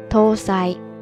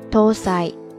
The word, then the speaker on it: 搭載: 这个时候呢，日语也是相同的汉字搭载，发音呢叫做 tosai tosai tosai，ですね。